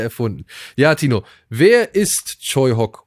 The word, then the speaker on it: Tsui Hark: Den kennt ihr eher vielleicht geschrieben als Tsui Hark, aber er wird tatsächlich Choi Hock ausgesprochen.